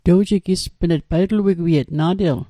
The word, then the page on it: wigwiet: Mi'gmaq